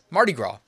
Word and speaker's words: Mardi Gras: I don't think you pronounce the S. Mardi Gras.